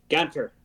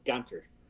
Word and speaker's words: Ganter: Ganter. – Ganter. – It's actually from Germany, so European name still, but Ganter. So just think that you can have a banter as you canter with Mr. Ganter.